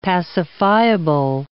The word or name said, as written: pacifiable